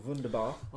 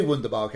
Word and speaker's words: Wunderbar: You can do Wunderbar, can you? – I can do it's Wunder, isn't it? Isn't the W pronounced as a V?